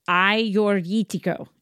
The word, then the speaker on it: Agiorgitiko: Ajorgitiko is how you say this grape. It looks like Ajorgitiko.